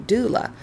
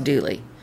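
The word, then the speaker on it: Dula: According to traditional North Carolina ballad singer Bobby McMillan, the Tom Dooley story is based on a murder that was committed by Tom Doola, sometimes locally pronounced Dooley, a Confederate Army veteran from Wilkes County.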